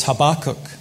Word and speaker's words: Habakkuk: Everyone from the East End of Glasgow knows it's Habakkuk.